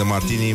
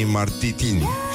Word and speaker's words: Martini: Și pronunță, în loc de Martini, Martitini.